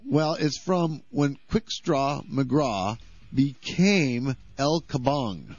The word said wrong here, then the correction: Quick Draw McGraw: Quick Draw McGraw.